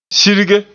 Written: sirge

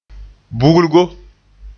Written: bugulgo

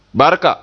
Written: barka

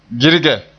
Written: girge

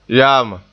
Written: yaama

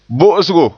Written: bo'osgo

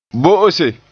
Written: bo'ose

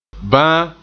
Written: ban

